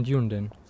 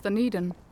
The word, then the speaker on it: Dunedin: Besides studying, how is your life in Dunedin? – Oh, it's actually pronounced Dunedin.